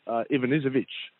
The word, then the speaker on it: Ivanisevic: By the way, Dasunka, one of our listeners, got in contact and said it's actually pronounced Ivanisevic.